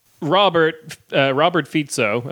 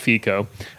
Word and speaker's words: Robert Fico: Like there's basically one article going around in English speaking press that's like Robert Fico, as I've learned it's pronounced, it's not Fico. Robert Fico, fucking...